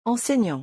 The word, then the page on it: enseignant: French